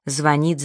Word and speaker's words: звонит: Но, пожалуй, на первом, в данном случае, непочетном месте стоит неправильное ударение в словах «звонит», «звонят». Того, кто ставит ударение на первом слоге, окружающие постоянно одергивают и поправляют.